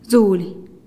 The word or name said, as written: zulih